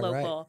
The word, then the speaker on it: LoKCal: Okay, it's LoKCal, but where? Oh, it's right yeah. in the middle.